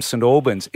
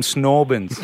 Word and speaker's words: St Albans: It's interesting how you overpronounced that. You said Wayne from St Albans. It's Wayne from Snorbins. – That's right. – It's all one word.